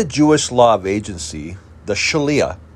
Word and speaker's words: Shaliah: We can look at the Shaliah, and it's pronounced S-H-A-L-I-A-H for my podcast listeners.